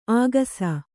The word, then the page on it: ಆಗಸ: āgasa